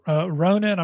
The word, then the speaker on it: Ronin: Another term, Ronin, I'm not sure if I'm pronouncing that right, R-O-N-I-N.